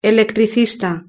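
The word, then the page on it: electricista: Spanish (Spain)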